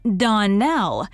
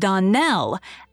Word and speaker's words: Donnell: "Clarice Almira told me that you pronounced our name Donnell. Now, Miss Shirley, the correct pronunciation of our name is Donnell, accent on the last syllable. I hope you'll remember this in the future."